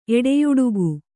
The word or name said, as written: ಎಡೆಯಾಡಿಕೆ